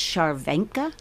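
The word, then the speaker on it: Scharwenka: So it's pronounced Shervenka.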